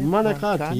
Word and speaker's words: manicotti: Well, yesterday I had manicotti. Is that how you say it? – Manicotti, yeah.